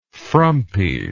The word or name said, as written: frumpiness